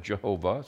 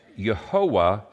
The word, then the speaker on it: Yehovah: Some people pronounce it Jehovah, some pronounce it Yahweh, but it's Yehovah said to Joshua.